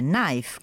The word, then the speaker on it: knife: Il verbo è irregolare, to know, new, known, e come ogni altra parola inglese che inizia con K e N si pronuncia come se la K non ci fosse. Un altro esempio è knife, coltello, irregolare al plurale, come molti dei sostantivi che finiscono in F ed E.